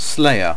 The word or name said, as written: slayer